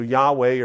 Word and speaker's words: Yahweh: So Yahweh or Yahweh is the way that's pronounced.